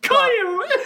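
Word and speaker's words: X Æ A-12: Which is apparently pronounced Kyle. – Kyle. – Fucking Kyle. – There's no way that is a pronunciation of Kyle.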